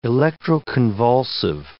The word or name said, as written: electroconvulsive